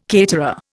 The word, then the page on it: caterer: Indian